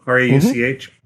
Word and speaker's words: Rauch: Rauch.